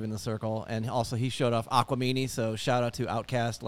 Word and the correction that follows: Aquemini: Is it not Aquamini? Have I been saying it wrong for 20 plus years? – It is not. You've been saying wrong for 20 plus years. – Damn, Dave, what is it? – It is Aquemini. – Aquemini. That's my bad syllable.